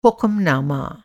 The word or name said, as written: हुक्म-नामा